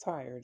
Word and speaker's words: Tyre: Although they pronounce his name Tyre, the place is pronounced Tyre also, I suppose, but as far as I know, it's pronounced Tyre, just like tire on your car.